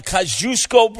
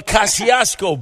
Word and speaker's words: Kosciuszko: It's sort of like the Kosciuszko Bridge or the Kosciuszko Bridge.